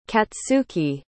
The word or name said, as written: katsuki